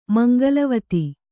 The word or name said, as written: ಮಂಗಲವತಿ